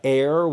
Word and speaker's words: Aer: They sent me their other product, Air, which is spelled A-E-R.